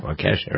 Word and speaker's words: Waukesha: All right, speaking of trials, here's the result of the trial in Waukesha? I think that's how you pronounce it. – Wisconsin, yeah, Waukesha.